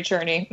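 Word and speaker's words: Czerny: And I think in addition to Jeremy Jordan's performance, I would like to mention Henry, and I think it's pronounced Cherney. – Henry Cherney. Mm-hmm.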